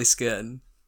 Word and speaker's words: Scone: And it's spelt scone. – So it is actually Skirn. – It's actually Skirn.